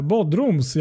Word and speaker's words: Bodrum: Och för att fortsätta i den här andan så kan vi även ta eh, Bodrums. Jag vet inte hur man säger, uttalar det där? Jo, men Bodrum. Bodrum, ja. Jag kan ju mm.